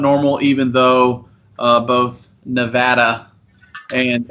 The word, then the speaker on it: Nevada: Nevada. – It is Nevada, not Nevada, folks. Nevada.